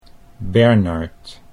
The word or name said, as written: Bernard